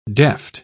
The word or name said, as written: deft